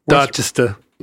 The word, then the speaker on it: Dorchester: Did you know there were parts of the greater Boston metropolitan area, Southie and Dorchester, am I saying that right? – Dorchester.